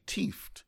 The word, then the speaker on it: tieft: let me pronounce it the Jamaican way, tieft, as in thieved.